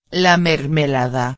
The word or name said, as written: la mermelada